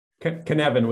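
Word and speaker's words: Cynefin: My question is, can we say that Cinefin Kinevin with converts the hard C.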